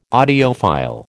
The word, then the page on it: audiophile: American English